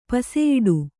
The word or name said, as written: ಪಸೆಯಿಡು